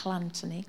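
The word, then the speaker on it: Llanthony: Double L at the front. Do you appreciate the pronunciation there? Llantony. Okay, don't sit too close on the front row.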